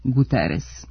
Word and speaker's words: Guterres: Tu S uvek ostaje S, pa Guterres u Portugaliji može da bude Guterres, ali kod nas on je Guterres.